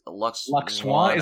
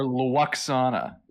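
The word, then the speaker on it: Lwaxana: Luxwana. Is it Luxwana or Luxana? Because I think it's spelled Luxana, but I think they say Luxwana.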